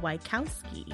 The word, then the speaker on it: Wachowski: And The Matrix, which is co-directed by Lana and Lily Wachowski. Is that how you say it? – Yeah, sounds about right.